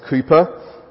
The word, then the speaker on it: Cowper: You may have heard of William Cowper, spelt Cowper but pronounced Cooper, a famous English poet.